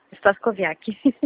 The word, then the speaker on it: Stakoviak: Por favor, eu falo com a senhora Glauciane Stakoviak. Pronunciei certo? – Stakoviak.